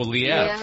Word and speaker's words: Liev: Oh, Liev.